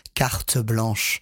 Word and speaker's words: carte blanche: If you use it in English, carte blanche. In French, carte blanche. – Yeah. – We have three syllables.